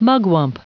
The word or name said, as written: mugwump